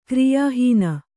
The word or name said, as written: ಕ್ರಿಯಾಹೀನ